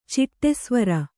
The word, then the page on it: ಚಿಟ್ಟೆಸ್ವರ: ciṭṭe svara